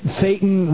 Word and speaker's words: Thetan: Do you find it ironic that the operating levels that you achieve in Scientology, the operating levels are called a Thetan level 3, 4, 5, 6, and on up, that Thetan rhymes with Satan? – Well, you know, I've seen that.